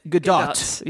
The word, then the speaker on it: Gadot: And Gal Gadot, Gadot. Yes, I'm trying. Yes, not French. – She's Israeli.